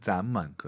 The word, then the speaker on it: 咱们: 우어만, 咱们. 그렇죠? – 没错. – 근데 보통 압도적으로 그럴 때는 咱们을 많이 쓰죠. – 네, 자몽이 아니에요. 咱们, 咱们, 이성경성.